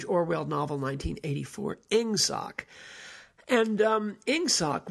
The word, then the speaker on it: Ingsoc: It was the shorthand for English socialism, but instead of being spelled E-N-G-S-S-C, as in the way we pronounce E-N-G in English, they had tightened it up to make it look exactly like it sounds, or sound like it looks, Ingsoc, I-N-G-S-S-C.